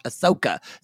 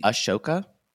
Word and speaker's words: Ahsoka: Ahsoka, so I know the backstory. Isn't it Ashoka? – No, it's Ahsoka.